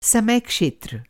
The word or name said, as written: समय क्षेत्र